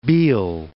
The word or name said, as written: Biel